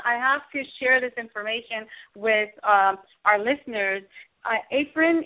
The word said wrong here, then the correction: Afreen: is your wife, right? – Afreen, yeah, she's my wife. – Afreen, I'm sorry, I wanted to make sure I pronounced it well.